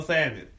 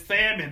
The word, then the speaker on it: salmon: You can't substitute this for salmon. And it's salmon, not salmon. Salmon. That ill is silent.